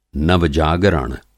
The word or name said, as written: नव-जागरण